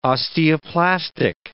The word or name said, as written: osteoplastic